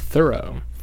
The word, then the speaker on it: Thoreau: I learned from NPR recently that it's actually pronounced Thoreau.